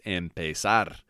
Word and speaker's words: empezar: And the verb of the day is empezar.